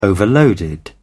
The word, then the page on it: overloaded: British English